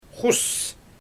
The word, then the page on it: xus: Xyzyl